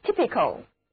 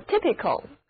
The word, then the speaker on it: typical: by type 最后一个字母 E 去掉，然后再加上 ICAL 这个形容词后缀，于是就得到了形容词 typical， typical，这个时候字母 Y 的读音就变成了短的 E，整个单词读作 typical。